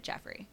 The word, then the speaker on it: Geoffrey: It's spelled like G-E-O-F-F-R-E-Y. And I feel like that's Joffrey, but some people pronounce it Jeffrey. – I think that way is pronounced Jeffrey, too, still.